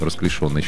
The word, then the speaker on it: расклешённый: В общем, придется принять, что ударение на третий слог расклешенный считается не... верный. – Неправильный. – Представляете? Правильно говорить. Вот поставьте букву «ё», и все будет понятно.